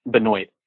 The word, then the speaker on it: Benoit: But apparently this guy, he is the one person whose name isn't pronounced the proper way. It's Benoit. Mm-hmm.